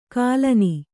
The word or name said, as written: ಗುಲ್ಲನೆ